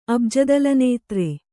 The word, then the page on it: ಅಬ್ಜದಲನೇತ್ರೆ: Kannada